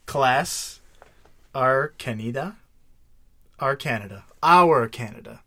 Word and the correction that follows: Arachnida: Archanida really is Arachnida. Duh. It's Arachnida. – Wow. You butchered that one. – And then order is Aranei. – That's the one that has an A-E-A.